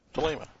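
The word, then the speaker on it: Thelema (θέλημα): Thelema. That's long A.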